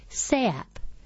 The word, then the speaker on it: SAAP: SAAP, spelled S-A-A-P, pronounced SAAP, which means delicious in Laotian.